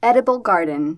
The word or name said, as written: edible garden